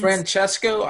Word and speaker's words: Francesco: And there's some guy that I'm friends with on Facebook, Francisco Iaqueta, I believe his name is. Francisco Iaqueta, Francesca Iaqueta. I'm not good with vowel sounds. – Francesco Iaqueta. – There it is, Francesco.